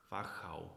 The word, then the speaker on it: Wachau: How's my pronunciation? Wachau. – Wachau, perfect. That's really good.